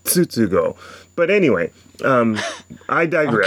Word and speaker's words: Tsutsugo: I think the people I heard one thing on Facebook, on YouTube, and the guy is not saying Tsutsugo. He's pronouncing the word as if the American style of saying tsunami, mm-hmm. by making the T silent. So he's saying Tsutsugo, and I'm like, this is a problem.